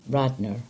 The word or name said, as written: Radnor